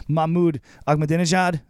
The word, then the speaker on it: Ahmadinejad: I can see Mahmoud Ahmadinejad. Is that how you say his name? – Yes.